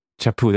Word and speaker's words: chapeau: It's called a chapeau hat. – I'm not French. I don't know how to say it. – Chapeau hat? Chapeau, there we go.